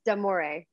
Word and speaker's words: D'Amore: No, I'm just kidding. It's D'Amore. I know D'Amore. Everybody does that because of the apostrophe.